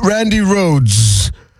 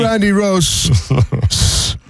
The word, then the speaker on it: Rhoads: Randy Rhoads. That's how you pronounce his name. It's not Randy Rhoads.